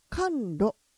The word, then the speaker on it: 寒露: 今の期間は寒露、寒露ですね。寒そうですもんね、寒露、うん、はい、寒いという字に梅雨と書いて寒露ですね。